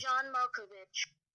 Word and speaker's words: Malkovich: Did I say the name wrong? – No, you said it right. I was just checking, because I've heard it pronounced Malkovich, but it's... – You've heard John Malkovich? – Yeah, which is wrong. I'm just confirming it's Malkovich.